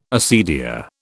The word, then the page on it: acedia: British English